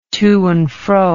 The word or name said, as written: to and fro